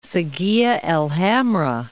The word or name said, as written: Saguia el Hamra